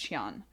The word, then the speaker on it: Qian: The Q's in Chinese are pronounced ch, so I think it's Qian.